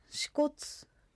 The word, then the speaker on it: 支笏: 支笏湖の支笏もこちらと関係あるようです。この支笏が支笏、死ぬに骨と書いて支笏という言葉に聞こえて縁起が悪いということで江戸時代に改名されましたが、昔千歳には鶴が住んでいたので縁起を担いで千歳という名前にしたとのことです。